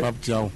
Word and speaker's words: Pape Thiaw: Pape Thiaw.